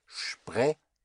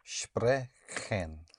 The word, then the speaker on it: sprechen: So to speak, you have spray, S-P, it's like an S-H-P, spray. And then you have C-H-E-N, the C-H-Chen, Chen. So you have sprechen to speak. How would you say it? – Sprechen.